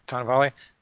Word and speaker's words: Cannavale: Those are the things Tony Cannavale was, am I saying his name right? Cannavale? Who's upset about? – Right.